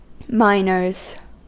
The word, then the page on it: miners: American English